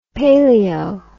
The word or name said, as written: paleo